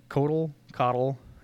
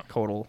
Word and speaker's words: Koatl: Ice Fang, K-O-A-T-L. – Oh, Coddle, okay. – Right. I don't know how to pronounce that. Coddle, yeah. – Coddle, something like that.